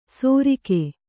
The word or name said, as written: ಸೋರಿಕೆ